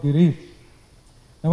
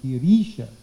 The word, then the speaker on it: Girisha: That should be a long I. Giri Isha would be Girisha with a long I. But there's no long I here.